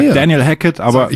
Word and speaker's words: Daniel Hackett: Ich spiele das nochmal ab. Das ist vom Euroleague, offizielle Euroleague Pronunciation Guide. – Nein. Daniel Hackett, Number Zero. – Das ist er selber. – Das ist er selber, ja, genau.